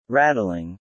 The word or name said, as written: rattling